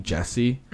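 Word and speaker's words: Jessy: Because I grew up with the nickname Jesse, mm-hmm. – and it's sure specifically did. Spelled with a... I did. It's yep. specifically spelled with a Y, J-E-S-S-Y, mm-hmm.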